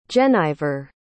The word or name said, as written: jennyver